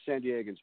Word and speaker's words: San Diegans: It's San Diegans, by the way.